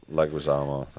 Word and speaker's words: Leguizamo: Leguizamo, that's it.